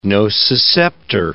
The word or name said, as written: nociceptor